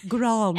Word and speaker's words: Grande: Pronounce the E. – Grande.